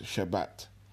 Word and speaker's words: Shabbat: So the Greek word is from the original Hebrew word that is pronounced Shabbat.